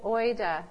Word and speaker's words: oida: But the word for know is the Greek word oida. It's spelled O-I-D-A.